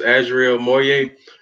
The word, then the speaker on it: Azial Moye: My name is Azial Moye.